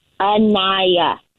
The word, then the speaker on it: Anaya: I'm sorry, you said it's what? – Anaya. – No, I've got Anya on my paperwork here. – This is ridiculous. Now you're telling me what my name is? – Well, it's spelled Anya, so I don't... – No, it's spelled Anaya.